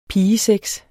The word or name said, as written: pigesex